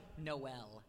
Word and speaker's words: Noel: Noel. N-O-E-L. Mm-hmm. Everyone says Noel.